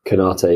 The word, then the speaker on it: Konaté: Um, Konaté, yeah.